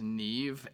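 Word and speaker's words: Niamh: Uh, her name is Neve Adkins, but it's spelled N I A M H A D K I N S.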